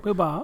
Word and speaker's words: Bilbao: Hoe spreek je dat op zijn Spaans uit? Jij bent Bilbao, eh, Bilbao.